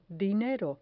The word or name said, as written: dinero